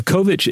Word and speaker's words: Kovic: This is K-O-V-I-C with the last part having a C-H pronunciation like in chocolate. So Kovich is a Swiss social scientist and,